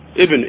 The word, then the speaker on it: ابن: فمن المرتبة العليا لذلك ما أطلق عليه بعض الأئمة أنه أصح الأسانيد كالزهري عن سالم بن عبد الله بن عمر ابن الألف ساقطة لكن تنطق.